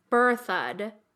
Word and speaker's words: Berthoud: But S.J. Weigel wrote in to say it's pronounced Berthoud.